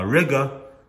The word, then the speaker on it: Riga: So even though I had to walk a little bit around Riga, which rhymes with nigga, I saved 2.5 pounds, which is good for me to buy, 2.5 pounds.